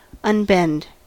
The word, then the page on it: unbend: American English